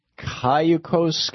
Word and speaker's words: Cayucos: John in Cayucos, California.